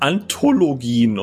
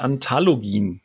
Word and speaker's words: Anthologien: Das heißt Anthologien und nicht Anthologien. Ist richtig, entschuldige.